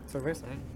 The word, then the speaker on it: cerveza: Cerveza. I'm gonna have to look up how to actually say that. – That's how you say it. – Cerveza.